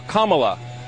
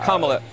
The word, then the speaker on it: Kamala: Fox, her name is pronounced comma, like the punctuation mark, Kamala. Okay? – Okay. – Seriously, I've heard every sort of bastardization. – So what? – That's how it is, Kamala.